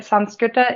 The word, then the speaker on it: Sanskrit: Sanskrita is the traditional way of pronunciating, and then Sanskrit is the English way of pronunciating it.